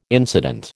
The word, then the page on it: incident: American English